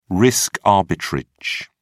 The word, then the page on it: risk arbitrage: British English